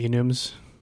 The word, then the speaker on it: enum: I think that's the right way to pronounce it. Enum, enum. I never know. Short for enumeration. – I call them enums.